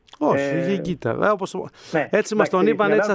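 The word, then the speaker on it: Higuita: Το λέω γιατί τα νεότερα παιδιά, εντάξει, μπορεί να μην τον ξέρουν, αλλά η ισπανική προφορά είναι η Γκίτα.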